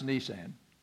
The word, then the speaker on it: Nisan: In the month of Nisan, that is not a car, by the way, Nisan, pronounced Nisan.